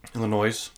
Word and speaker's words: Illinois: You pronounce the S in Illinois? – Illinois.